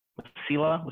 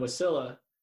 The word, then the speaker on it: Wasilla: I don't know if I've ever heard that word pronounced out loud. Wasilla? Wasilla? – Wasilla. – It's definitely not Wasilla.